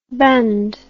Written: bandh

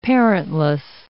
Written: parentless

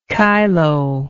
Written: kyloe

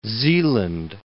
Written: Zeeland

